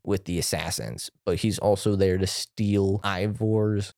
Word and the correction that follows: Eivor: Can't remember how they pronounce it. – Eivor.